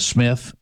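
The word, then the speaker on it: Psmith: The Jeeves series and the Smith, it's spelled P. Smith, but the P is silent.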